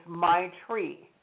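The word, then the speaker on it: maitri: And so the love is my tree, M as in Michael, A-I, T as in Tom, R-I, and it's pronounced my tree. So you think of a tree. Think of your tree, my tree. Okay, the second one is Karuna, K-A-R-U-N as in Nicholas, A, Karuna.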